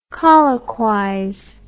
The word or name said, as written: colloquize